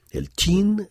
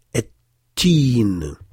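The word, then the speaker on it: Et-Tin (التين): Mais en réalité, il faut prononcer En-Nil, Et-Tin. C'est un phénomène phonétique qui fait que le lam de l'article cède la place à la première consonne du nom, et donc on prononce deux fois le nun pour ennil et deux fois le ta pour ettin.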